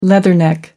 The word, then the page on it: leatherneck: American English